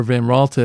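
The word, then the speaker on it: Van Raalte: In 1846, Van Ralte, or Van Ralte as the Dutch would pronounce the name, made the decision to immigrate.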